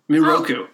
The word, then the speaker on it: Miroku: Mikuro or Miroku? – Miroku. – Miroku, okay. Miroku and Sango.